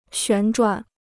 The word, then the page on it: 旋转: xuán zhuǎn